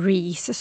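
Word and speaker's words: Rhys: Där Rice the bad guy, eller Reese som han egentligen ska uttalas då, där, men Rice säger jag.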